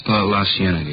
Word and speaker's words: La Cienega: Street out that way, La Cienega, is that how you say it? – La Cienega, yeah.